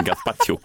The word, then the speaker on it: gazpacho: Ja, med panna rakt ner i min tomatsoppa i min gazpacho. Gazpacho, det är som man uttalar det.